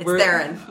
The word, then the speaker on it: Theron: Yeah, It's Theron.